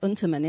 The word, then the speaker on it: Unterman: Well, we pronounce it Unterman. In London, people might call it Unterman, but it's a German name. And really, it should have two N's at the end, but one of them dropped.